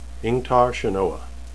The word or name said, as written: ingtar